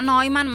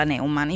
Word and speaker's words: Neumann: Sì, alla tedesca si pronuncia Neumann, ma siccome lui è spagnolo, mi ha spiegato Alessandra Tedesco, si fa chiamare alla spagnola Neumann.